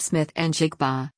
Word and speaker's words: Smith-Njigba: Smith-Njigba, pronounced Smith-Njigba,